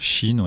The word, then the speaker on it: Shein: C'est l'équivalent de Shein, c'est comme ça que ça se prononce ? – Shein, oui.